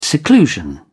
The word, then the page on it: seclusion: British English